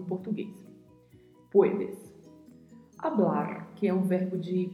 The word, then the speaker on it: hablar: A particularidade de pronúncia fica aí a cargo do vibrato do R ao final da palavra, hablar.